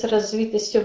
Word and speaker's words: развитостью: а оно развивается вместе с развитостью вашей души. Развитостью вашей души, правильно, ударение, так будет.